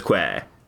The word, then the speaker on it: quay: Eh, isn't it, it, eh, I guess I suppose it's pronounced key, but I always, I prefer pronouncing it as quay.